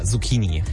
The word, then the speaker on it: Zucchini: Also spricht man das mit K oder mit Ch? Zucchini oder Zucchini? – Ja, mit K, Zucchini.